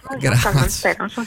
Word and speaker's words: Jón Kalman Stefánsson: Quando sono andato in Islanda nel 2019, ho conosciuto Jón Kalman Stefánsson, ora non so se la pronuncia è corretta. – Bravissimo, Jón Kalman Stefánsson, sì.